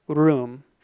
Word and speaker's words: ruwm: It's the word R-U-W-M, room. Everyone try saying that, room. If you can roll your R, that's even better.